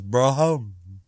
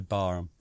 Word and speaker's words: Barham: And now, well, one thing is, before there, people write in and say, can't pronounce Barham, because the real naval officers pronounce it in some ludicrous fashion as Braham, Braham, Braham, Braham, Braham, Braham, Braham. But I don't. I say Barham. – Not Barham. – Occasionally I say that as well. I know I don't say it right, but Sodom.